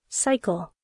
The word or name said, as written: cycle